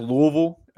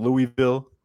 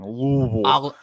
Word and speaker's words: Louisville: It's pronounced Louisville. If you're from Kentucky, so stop saying Louisville. No one says it in the state. All right, it's Louisville. Like say like you're choking on your tongue.